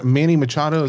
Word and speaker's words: Manny Machado: Is that Manny Machado? Is that how you pronounce it? – Mm-hmm.